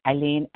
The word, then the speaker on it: Ilene: My name is Eileen, and I spell it I-L-E-N-E.